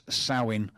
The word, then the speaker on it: Samhain: Halloween's origins date back to the ancient Celtic festival of Samhain, pronounced Samhain.